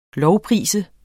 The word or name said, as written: lovprise